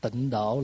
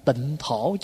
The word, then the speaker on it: tịnh thổ: Thật ra người Việt mình đọc là độ, chứ đúng ra phải đọc là thổ. Tại vì chữ tàu khi mà viết á, là tịnh thổ chứ không phải tịnh độ.